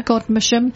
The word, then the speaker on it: Godmersham: at Godmersham Park. Have I pronounced that right? – I think so. – Godmersham, CT4 7DT, and it's free parking.